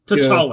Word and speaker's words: T'Challa: T'Challa, yeah.